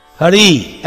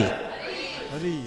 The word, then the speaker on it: Hari: Ha, Yeah, not just her, and not just Re. Right, Krishna Kumar? Not just Ra. Hari. You need both syllables. Hari. Everybody. – Hari.